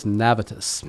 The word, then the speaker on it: Navitas: So I say it different every time, but it's Navitas.